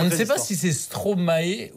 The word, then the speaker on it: Stromae: Stromae, non, ça marche pas, c'est Stromae. – Stromae, je connais pas. – Il manquait des syllabes.